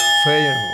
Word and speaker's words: Feyenoord: Feyenoord.